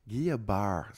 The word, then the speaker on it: Guillain-Barré: She's in a place called Harbor Chase Assisted Living, and she's suffering from a condition called Guillain-Barré syndrome. It's French, and I just pronounced it the way it's supposed to be, but it's not spelled that way.